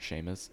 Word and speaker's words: Shamus: I know that, so my name, like Seamus, it's Irish, but the way that it's spelled, S-H-A-M-U-S, it's the Americanized version.